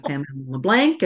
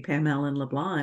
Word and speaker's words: Pam Allen-LeBlanc: And so I tell people I mispronounced my own last name 3 times. I told them I was Pam Allen LeBlanc and Pam Allen LeBlanc. And then finally I said it correctly, Pam Allen LeBlanc.